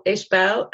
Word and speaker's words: Ishbel: So it's Ishbel, Ishbel, and then Myerscough, Myerscough.